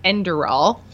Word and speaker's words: Enderal: Jim says, first of all, that it's pronounced Enderal. And he says Enderal is a full conversion mod with all new story, voice acting, and such.